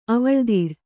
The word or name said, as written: ಅವಳ್ದಿರ್